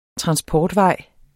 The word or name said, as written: transportvej